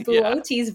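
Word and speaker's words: Boötes: Like, I didn't know how to pronounce that. – Nobody does. It's Bootes, and there's two of the dots above the second O.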